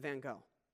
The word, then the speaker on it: Van Gogh: And they go back to the time and the life of Vincent van Gogh. And they meet this painter. And by the way, in English, in British culture, it's pronounced Van Gogh.